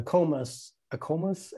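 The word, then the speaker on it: AACOMAS: There's the AMCAS, which is for MD schools, and there's the ACOMAS application. Is that I think so. That's how you say it.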